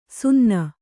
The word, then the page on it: ಸಿನ್ನೆ: sinne; kannada